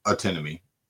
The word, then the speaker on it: antinomy: Am I saying that right? – Yeah, antinomy. – antinomy, or paradox to describe the problems encountered in these doctrines.